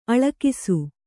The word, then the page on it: ಅಳಕಿಸು: Kannada